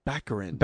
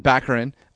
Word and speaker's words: Baccarin: You have to say it right. It's Baccarin. – Baccarin.